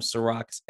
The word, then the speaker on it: Serak: But like I said, type in the weekly review, or you can just type in my name, Ciroc, S-E-R-A-K. Yes, I know, unfortunately, not spelled like the liquor, but it tastes just as good, as well as YouTube, the weekly review.